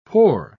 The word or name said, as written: pore